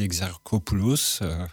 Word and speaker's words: Exarchopoulos: Oui, Quentin Dupieux, qui fait un film par an, à peu près, depuis des années, avec principalement une énorme performance d'Adèle Exarchopoulos, sans l'écorcher son nom, bravo.